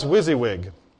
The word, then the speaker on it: WYSIWYG: It's pronounced WYSIWYG.